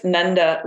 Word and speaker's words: Nanda: What is it? Say it right. I definitely said it wrong. What was it? – It's Nanda, Nanda is Krishna's father, son of Nanda.